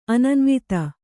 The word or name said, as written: ಅನನ್ವಿತ